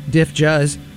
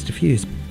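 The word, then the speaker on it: Dif Juz: This is from a band called Diffuse, or also known as Diff Juz, as I've mispronounced it for years and years and years. It's D-I-F-J-U-Z, and I learned not that long ago that it's Diffuse.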